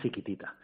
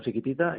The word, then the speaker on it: Sikitita: Siquitita como la canción de ABBA. – Sí, bueno, no sé si irá por ahí o... Pero bueno, no, porque se escribe distinto, se escribe S-I-K-I-T-I-T-A, ¿no? – Con K de kilo, correcto, eso es.